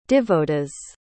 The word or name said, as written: divodas